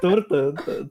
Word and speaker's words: торта: Торта же, да? – Торта! – Торта! Ударение, ударение всегда на О.